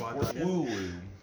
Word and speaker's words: Wooloo: Wooloo. – Wooloo? – Yes. – How do you even spell that? – W-O-O-L-O-O.